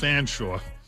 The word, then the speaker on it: Featherstonhaugh: The spelling of that is F-E-A-T-H-E-R-S-T-O-N-H-A-U-G-H. And I'm assured by those who knew him, it's a moot point now. You didn't know him probably, and it's perhaps of marginal consequence. But it's pronounced Fanshawe.